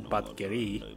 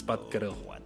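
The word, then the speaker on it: Batgerel: It's not Batgeri, it's Batgeril. Well, if you're Buryat Mongolian, you would say Batgeril. If you are Khalkha Mongolian, which Batgeril is, you would pronounce it as Batgeril.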